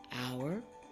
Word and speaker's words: hour: Then we have a word like hour and honor. The words start with H, but you don't pronounce the H.